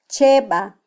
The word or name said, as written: cheba